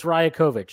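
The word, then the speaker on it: Rajaković: Please pronounce the coach's last name correctly or just call him Darko. I looked it up. It's Rajakovic.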